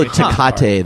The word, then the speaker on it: Tecate: I like how Tecate, though, is full TK. And it's pronounced correctly. Yeah. Tecate.